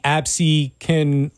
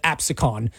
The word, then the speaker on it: Absecon: I did a video on the, I now know it's called the Absecon Lighthouse, but in the video I called it Absecon.